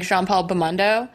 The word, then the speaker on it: Jean-Paul Belmondo: And his character, because in the original, how would you say his name? Like Jean-Paul Belmondo? – Yeah.